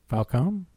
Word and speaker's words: Falcone: Is it Falcone or Falcone? – Falcone. – Because in the Nolan stuff, they call him Falcone. – Yeah, it's Falcone.